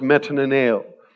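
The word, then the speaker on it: metanoeo (μετανοέω): It simply means this, that the Greek word is pronounced metanoeo, and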